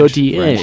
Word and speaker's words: Gauthier: How do you pronounce it? – Gauthier? – It is Gauthier.